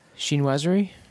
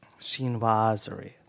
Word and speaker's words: Chinoiserie: Chinoiserie. – Chinoiserie. – Yes. – Chinoiserie. – Yes. It's okay. the French term for Chinese-esque. – I like Chinese-esque better. Okay. I can say it. – Okay. Chinoiserie.